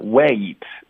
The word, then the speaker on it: wait: 这里呢，wait，往往是一个发音痛点。